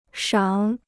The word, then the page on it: 赏: shǎng